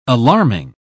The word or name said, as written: alarming